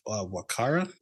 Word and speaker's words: Wakara: Chief Wakara, which is, threw me off because it's spelled like Walker, but pronounced Wakara.